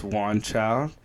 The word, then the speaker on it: Juan Chow: We have our special guest, Juan Chow.